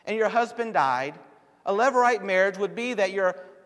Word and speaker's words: levirate: It's called, I never can pronounce it correctly because I kept trying to use a different kind of word, but it's levirate, L-E-V-I-R-A-T-E, marriage.